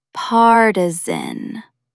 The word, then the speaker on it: partisan: Stress the first syllable. Partisan.